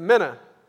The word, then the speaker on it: mina: And he got to the place of a man had a mina, and he looked at me and he said, how do you say that? I said, mina.